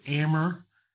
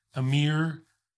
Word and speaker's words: Amer: So Amer is the best way you could ever pronounce it. Everybody says Amer, Amir, Amer, which is really frustrating.